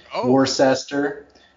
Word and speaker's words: Worcester: It's spelled W-O-R-C-E-S-T-O-R. Worcester. So everybody called it Worcester. Nobody could pronounce it.